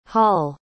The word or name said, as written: holle